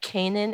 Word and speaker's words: Canaan: The gram is Nicole Canaan, but uh, my name is my last name is spelled like Canaan in the Bible because most people do not know how to spell it.